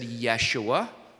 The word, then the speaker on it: Yeshua: Now, in Hebrew, they wouldn't have said Jesus. That's how you say it in English. They would have said Yeshua, or Joshua was the name Joshua, named after that famous military leader in the Old Testament.